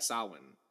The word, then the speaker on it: Samhain: Yeah, Samhain. Win more than Wayne.